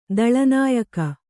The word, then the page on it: ದಳನಾಯಕ: Kannada